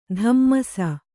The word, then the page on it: ದಮ್ಮಸ್: dammas